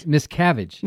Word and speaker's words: Miscavige: She is, am I saying that right? – Miscavige. – Miscavige. That's spelled M-I-S-C-A-V as in Victor, I-G-E, Hill, author of the new book, It's getting a lot of publicity.